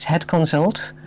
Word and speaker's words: Het Consult: And that would be, yes, I'll pronounce it in Dutch. It's Het Consult, which would be H-E-T, and then the word consult.